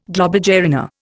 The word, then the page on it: globigerina: Indian